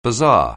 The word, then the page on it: bazaar: anglais